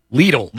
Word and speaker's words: Lidl: Lidl rhymes with needle. Lidl.